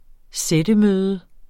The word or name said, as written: sættemøde